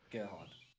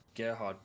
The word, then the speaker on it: Gerhard: Was it Gerard Berger? – Gerhard. – Huh? – Gerhard Berger, buddy.